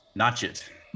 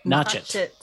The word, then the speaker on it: Nochet: Uh, in Nochet, I believe is pronounced. Nochet. – Nochet. Thank you.